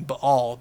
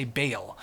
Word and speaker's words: Baal: Sometimes you'll hear this pronounced Baal. That's the proper Hebrew pronunciation, Baal, but most of us Westerners will say Baal.